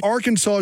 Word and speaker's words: Arkansas: Arkansas is the way you have to pronounce it.